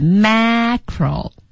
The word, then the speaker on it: macro: 区别是啊，第一个大的读音是 macro。梅花 a 音，小的呢是 micro，是个元音 i， micro，是的，所以一个大一个小，哎，大家把发音也要注意一下。